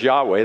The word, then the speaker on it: Yahweh: And when it's capitalized like that, the Hebrew people would pronounce that as Yahweh.